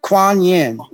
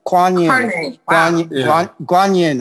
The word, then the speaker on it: Kuan Yin: Kuan Ye? What's his name? – Kuan Yin. Kuan Yin, I think, is the original pronunciation with like a G.